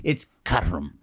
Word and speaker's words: Caterham: Obviously, it's not pronounced Caterham. It's gonna Caterham? – It's Caterham.